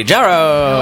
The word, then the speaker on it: Jarreau: And Marcy Jarreau. – Hello, I'm the shyest woman at UCB. – Yeah. – Say your last name again. – Jarreau. – I've been saying it wrong for a long time.